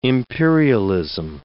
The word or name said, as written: imperialism